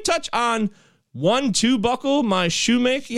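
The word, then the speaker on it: Shewmake: It's S-H-E-W, Maki, M-A-K, Shoemaker. It looks like Shoemaker, but I'm going to go with Shoemaker, Braden Shoemaker.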